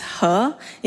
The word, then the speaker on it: He: Hello everyone、 my name is He, is spelled as H-E, and that's it Yes, it is my name.